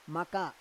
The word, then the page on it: maka: Roon